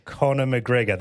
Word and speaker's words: Conor McGregor: Conor McGregor, that's right.